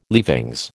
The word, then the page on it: leavings: American English